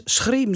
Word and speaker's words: schrieven: En misschien weet je ook wel dat je in het Drents schriem zegt, maar schrieven opschrijft.